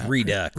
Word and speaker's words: Redux: I don't know how you exactly pronounce R-E-U-D or R-E-D-U-X. – Well, we're in America, so it's Redux.